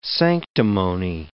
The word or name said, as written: sanctimony